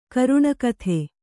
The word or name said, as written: ಕರುಣಕಥೆ